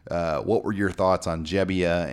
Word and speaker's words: Gebbia: Now, is it Gebbia or Gebbia? I want to make sure I get this right. – It's Gebbia. – Okay, we've been saying Gebbia. Mm-hmm. We've been saying the kid's name wrong all year.